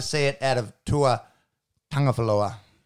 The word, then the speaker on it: Tagovailoa: It's it's a silent NG. – Uh-huh. – Tagovailoa. – Tagovailoa.